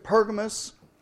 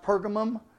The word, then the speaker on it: Pergamos: We looked at Pergamos, sometimes pronounced Pergamum, last week.